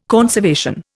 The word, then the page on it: conservation: Indian